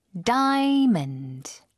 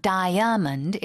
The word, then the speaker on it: diamond: Diamond. – die, 그 다음에 Yeah, it's two syllables. It's not diamond, it's diamond.